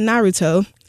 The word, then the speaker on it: Naruto: Oh, here's a question about Naruto.